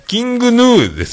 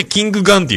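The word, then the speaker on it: King Gnu: えー、キング、えキングヌーですよね。人によってキングガンって読みますよね。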